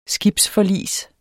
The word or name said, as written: skibsforlis